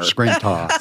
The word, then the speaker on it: Scranton: Don't you dare. – Scranton.